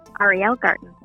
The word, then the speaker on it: Ariel Garten: Ariel Garten.